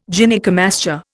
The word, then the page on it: Gynaecomastia: Indian